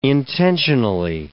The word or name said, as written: intensionally